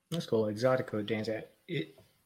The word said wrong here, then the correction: Zydeco: I pronounced it right. Exotico? – Zydeco. Yeah. – Zydeco. – Mm-hmm. Gotcha. Z-Y-D-E-C-O.